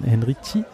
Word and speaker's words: Henrici: Es gab äh, eine kleine Abschiedsrede fast schon von Christian Henrici. – Richtig, ja. Mit Z ausgesprochen.